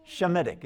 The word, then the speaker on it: Semitic: Shemitic is actually how it should be pronounced if we pronounced it in the way that it's pronounced in the original. Shemitic. And so Semites are not are Shemites.